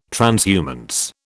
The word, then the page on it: transhumance: British English